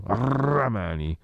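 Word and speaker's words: Rrahmani: Poi lo sapete, sta tenuto banco la polemica per la partita Juve-Napoli eh, tamponi al Napoli, positivo solo Ramani, è 2 R quindi lo pronuncio Ramani.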